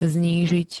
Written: znížiť

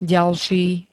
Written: ďalší

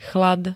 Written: chlad

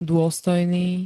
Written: dôstojný

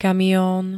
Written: kamion

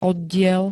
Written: oddiel

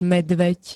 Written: medveď

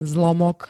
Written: zlomok

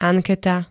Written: anketa

